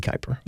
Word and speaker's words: Kuyper: I think some people pronounce it differently, but I would say Kuyper.